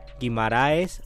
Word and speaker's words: Guimarães: Guimaraes Rosa. Guimaraes se escribe como suena. Así como suena.